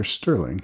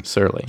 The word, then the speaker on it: Serling: Is it pronounced Serling or Sterling? – Serling. – Serling, yeah. yeah. No T in there. Yeah, that's right. No T.